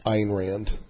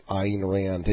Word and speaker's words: Ayn Rand: And the whole, you know, dystopian, you know, Anya Rand aspect to it. – Ayn Rand. – Well, I've heard so many different pronunciations. – They're wrong. I've read up on it. It is definitely Ayn Rand, and you can be for sure because there's a YouTube Donahue clip where he interviews her, and it's definitely Ayn Rand.